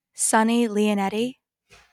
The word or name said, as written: Lionetti